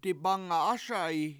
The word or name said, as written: dibAnga7ASa7i